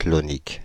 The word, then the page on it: clonique: France (Île-de-France); /klɔ.nik/